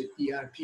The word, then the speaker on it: ERP: Kurumsal kaynak planlaması, İngilizcesiyle Enterprise Resource Planı, kısaca ERP diye yazılıp İngilizce ERP diye okunuyor.